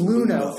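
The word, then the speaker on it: Luhnow: But now, seeing Derek Vigoa and seeing Lenhow, is that how you pronounce his name? – I believe it's Luno. Thank you.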